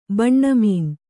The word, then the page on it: ಬಾನ್ಮೀನ: bānmīna